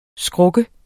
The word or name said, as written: skruk